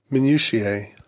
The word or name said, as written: minutiae